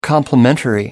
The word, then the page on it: complimentary: American English